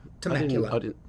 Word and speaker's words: Temecula: Temecula.